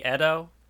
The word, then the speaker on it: Edo: Also, I notice you keep pronouncing it Ito. Wouldn't it be Edo? – Yeah, probably.